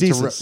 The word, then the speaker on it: Desus: Yeah, it's meant Desus. To rhyme with like these nuts and Jesus.